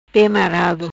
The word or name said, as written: Marado Pe